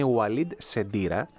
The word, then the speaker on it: Walid Sedira: Ναι, γράφεται και ξαναγράφεται το όνομα του Αλίντ Σεντίρα, όπως είναι, γιατί είναι Μαροκινός, έχει και ιταλικό διαβατήριο, ωστόσο, επειδή έχω φίλους που μπορούν να μου πούν ακριβώς πώς προφέρεται το όνομά του, είναι Walid Sedira.